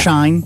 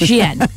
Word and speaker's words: Shein: They have tweeted it on their own account and said it's pronounced Shein.